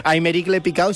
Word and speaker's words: Aymeric Le Picaud: Aymeric Le Picot. – Qué bien pronuncias el francés. – Picaud. Aymeric Le Picaud, si lo queréis buscar en Google.